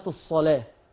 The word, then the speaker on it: الصلاة: احنا عندنا القرآن لما حضرتنا بنفتح المصاحف، دلوقتي بنفتح المصحف بنلاقي كلمات مكتوبة بكيفيات معينة، كلمة الصلاة، طلع كلمة الصلاة وطلع كلمة الصلاة لو سمحت، هتلاقي كلمة الصلاة مكتوبة ازاي؟ مكتوبة بواو. حضرتك وحضرتك بتتلفظي بواو ولا بتتلفظي بألف؟ أنا بتلفظ بألف يا شيخ، لكنها كتبت في المصحف بواو.